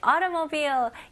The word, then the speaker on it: automobile: 네, automobile. 이 단어도 꽤 어렵죠? 오케이. 같이 한번 연습해 볼게요. 자, 여기서는 auto, T를 강하게 할 필요 없어요. 미국식 발음으로는 auto, auto. 약간 가벼운 D 발음으로 생각하시면 좋아요.